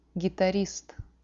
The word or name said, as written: gitarist